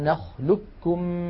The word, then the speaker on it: نخلقكم: ألم نخلقكم من ماء مهين، ننطقه بكاف مشددة.